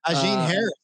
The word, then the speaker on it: Ajin Harris: Ajin Harris. – Yeah. Okay. I wasn't sure if that was going to be like an H.